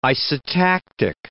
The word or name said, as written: isotactic